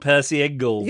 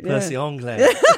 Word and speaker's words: Percy Ingle: All I'm saying is, I walked past one today as it happens, and it doesn't look like the kind of place that would have a French pronunciation. – Has it got an S on the end? – Well, even if it hasn't, I mean, it definitely looks more like Percy Ingle than it does Percy Anglais.